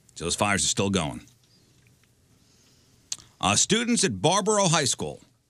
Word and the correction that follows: Baraboo: I'm sorry, I'm saying that wrong. Is it Baraboo High School? B-A-R-A-B-O-O, Baraboo.